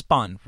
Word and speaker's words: Spahn: So what would a typical night at the ranch, I've only read it, it's a Spahn ranch is how you say it, right? – Yes.